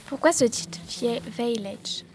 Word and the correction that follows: Vielleicht: Alors, je reprends la prononciation. C'est Vielleicht, on prononce. C'est de l'allemand.